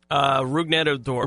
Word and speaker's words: Rougned Odor: Rougned Odor.